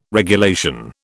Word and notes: regulation: British English

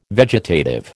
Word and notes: vegetative: American English

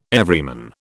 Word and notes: Everyman: British English

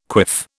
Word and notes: quiff: British English

